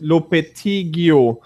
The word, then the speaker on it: Lopetegui: Men Lopid, nu måste jag uttala rätt och säga att jag säger det rätt, Lopetegui, tränaren i Porto, har ju verkligen med sin karismatiska utstrålning tagit det här Porto långt.